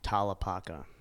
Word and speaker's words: Tallapaka: Tallapaka.